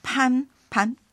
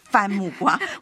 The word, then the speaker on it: 番木瓜: 是，好，嗯，再来一个叫攀攀，对不起，番木瓜，我老是呢把这个字念成攀啊，替他冠上了姓。